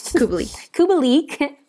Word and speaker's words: Kubalik: Kubalik has been a great, is that how you pronounce that? – Kubalik.